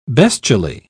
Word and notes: bestially: British English

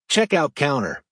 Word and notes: checkout counter: American English

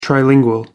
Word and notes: trilingual: American English

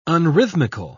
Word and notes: unrhythmical: British English

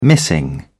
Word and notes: missing: British English

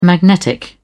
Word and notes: magnetic: British English

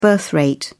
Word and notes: birth rate: British English

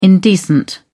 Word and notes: indecent: British English

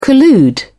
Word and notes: collude: British English

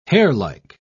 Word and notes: hairlike: British English

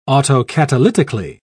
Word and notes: autocatalytically: British English

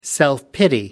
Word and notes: self pity: American English

